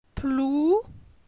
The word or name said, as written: Plou